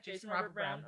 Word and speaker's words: Jason Robert Brown: Jason Robert Brown. – Did I say it right? – Yeah, Jason Robert Brown. – Okay, that's what I thought.